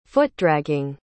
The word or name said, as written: foot-dragging